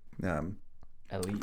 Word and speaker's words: elite: And it's spelled E-light, but it's said, like, elite. – Pronounced elite.